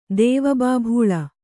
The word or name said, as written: ದೇವಬಾಭೂಳ